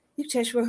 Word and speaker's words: Yukteswar: Well, book two on your list is The Holy Science by Swami Sri, and I'm not sure if I'm pronouncing this right, Yukteswar? – Yukteswar, who was the guru of Yogananda.